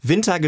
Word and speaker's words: Vinta: Aber äh, ja, Winter geschrieben V-I-N-T-A, ausgesprochen wie die Jahreszeit.